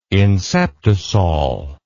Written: inceptisol

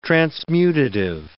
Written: transmutative

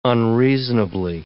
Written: unreasonably

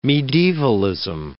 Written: medievalism

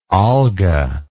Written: Olga

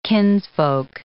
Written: kinsfolk

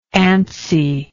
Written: antsiness

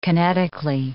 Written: kinetically

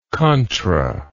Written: contra

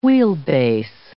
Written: wheelbase

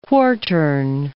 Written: quartern

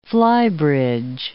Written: flybridge

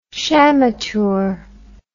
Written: shamateur

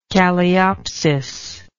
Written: calliopsis

